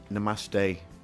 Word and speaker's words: namaste: So when DJ Skis comes in, I'll namaste to him. Am I pronouncing that right? – Yeah.